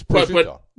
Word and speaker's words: prosciutto: I'm saying in Brooklyn or wherever they say brajute. Oh, yeah. – Right. It's prosciutto.